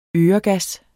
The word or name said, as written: øregas